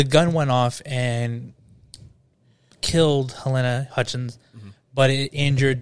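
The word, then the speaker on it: Halyna: Halen Hutchins. I think that's how you say her name. Helena. It's Helena.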